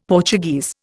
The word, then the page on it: Portuguese: Indian